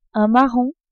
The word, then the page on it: marron: French